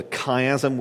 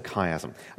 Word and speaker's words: chiasm: We've seen those once or twice in the Psalms and so on, named for the Greek letter chi, you know, shaped like an X that's pronounced as a hard CH. So we call this a chiasm.